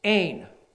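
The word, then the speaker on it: Ein (ἦν): It's the Greek word ein. Long E, N. Ein.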